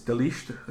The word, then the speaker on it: de Ligt: It's De Ligt. It's like some Dutch guy. D-E-L-I-G-T. It's like De Ligt or something like that.